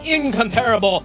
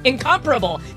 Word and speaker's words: incomparable: And of course, as always, behind the glass, we have the incomparable queen of knobs and sliders, producer Sammy. I pronounced incomparable wrong. I don't know. Incomparable. The incomparable queen of knobs and sliders, Sammy! Okay, that's much better.